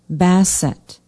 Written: bassett

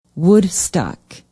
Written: woodstock

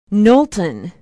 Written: knowlton

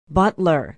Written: butler

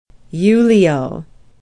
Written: ulao

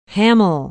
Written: hammel

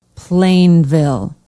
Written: plainville

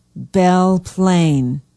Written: belle plaine

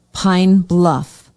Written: pine bluff

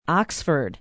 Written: oxford

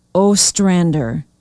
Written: ostrander

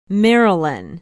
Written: merrillan

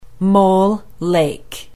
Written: Mole Lake

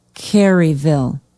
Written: caryville

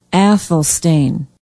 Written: athelstane

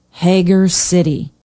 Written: hager city

